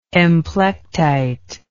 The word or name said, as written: emplectite